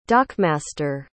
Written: dockmaster

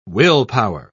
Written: willpower

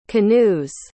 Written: canoe's

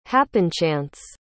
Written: happenchance

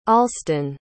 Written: alston

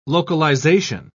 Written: localization

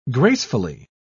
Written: gracefully

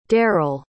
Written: deryl

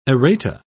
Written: errata

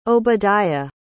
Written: Obediah

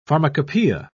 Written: pharmacopoeia

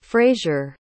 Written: frasier

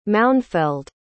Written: Maunfeld

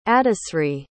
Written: adisri

